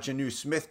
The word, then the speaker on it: Jonnu: Yes, you got Jonnu Smith correct and go.